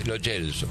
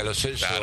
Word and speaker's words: Lo Celso: Si es italiano el apellido, si el origen del apellido es italiano, es Lo Celso. – Sí, más allá de que... – Por más que la familia le diga Lo Celso, está mal pronunciado.